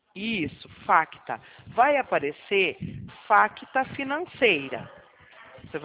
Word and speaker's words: FACTA: de faca, A de amor, C de casa. T de tatu, A de amor. Facta. O C de casa é mudo.